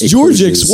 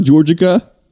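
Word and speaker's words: Georgics: Like, it's Georgix. Watch out. It's not Georgica. It's Georgix.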